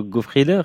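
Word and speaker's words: Goffriller: C'est aussi un album joué Vivaldi, Ophélie Gaillard, qui rend honneur à votre instrument, le Francesco Goffrieder, c'est comme ça qu'on prononce? – Oui, absolument.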